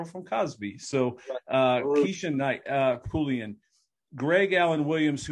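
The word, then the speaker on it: Pulliam: Am I saying it right? – Pullian.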